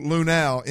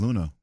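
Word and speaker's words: Luhnow: By the way, for the record, Crane did pronounce Luno Lunau in his presser, for the record. – So what is the correct way to pronounce? Because I always thought it was Luno. – I think it's Luno, but I'm just telling you what Crane said, bro.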